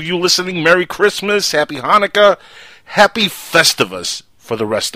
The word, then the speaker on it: Hanukkah: But you got to pronounce it right. It's Hanukkah. – You got to get the phlegm going.